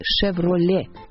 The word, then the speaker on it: Chevrolet: Поэтому фамилию и, соответственно, название компании следует произносить «Chevrolet» без «т» на конце.